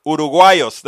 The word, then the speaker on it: uruguayos: Uruguayos, that's how you say it.